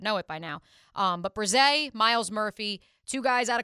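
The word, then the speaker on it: Bresee: Clemson's Miles Murphy and then Brian Breesy. – Brise. – I'm going to keep mispronouncing it.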